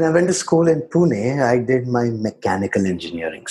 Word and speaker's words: Pune: And you also went to school in Pune, if I said that right.